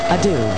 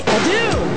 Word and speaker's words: Adu: Last name is A-D-U. Adieu. Sade. Adieu.